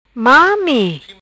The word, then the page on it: Mami: Patua